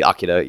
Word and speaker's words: Akira: If you're going strictly off the Japanese, it would be Akira, yes, and then it would be Amon.